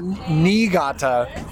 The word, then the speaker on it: Niigata: Niigata.